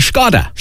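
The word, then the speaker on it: Škoda: And the well-known car maker Skoda is actually Skoda. – Skoda. – Skoda. – Skoda. – So we're supposed to go with the Czech pronunciation, obviously being a Czech company.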